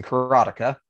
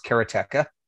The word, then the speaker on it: Karateka: for my entire life, have been pronouncing Karateka. But having just watched an interview with him, it is apparently pronounced Karateka.